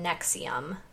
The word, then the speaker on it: NXIVM: And then the other one that I really loved is called Uncover Escaping Nexium, which is spelled NXIVM.